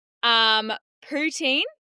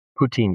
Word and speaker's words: poutine: Poutine. Am I saying that right? – Poutine, yeah.